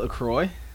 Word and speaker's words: LaCroix: Is it LaCroix? – Yeah.